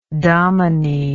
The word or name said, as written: domine